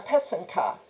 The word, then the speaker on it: pysanka: A single Ukrainian Easter egg is called a pysanka. It's spelled P-Y-S-A-N-K-A. The plural for many Ukrainian Easter eggs is pysanke, spelled P-Y-S-A-N-K-Y.